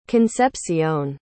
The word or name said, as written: concepcion